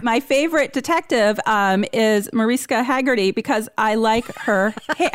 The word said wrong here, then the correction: Hargitay: how do you say her name? – Hargitay. – Mariska Hargitay.